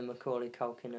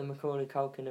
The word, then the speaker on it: Macaulay Culkin: Do you feel like the Macaulay Culkin of... Is that how you say his name? – Yes, that's how I say his name. – Do you feel like that, the Macaulay Culkin of comedy? – No.